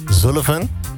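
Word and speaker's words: Sullivan: Hoe spreek je die achternaam uit? – Zullivan.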